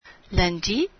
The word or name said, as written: lundi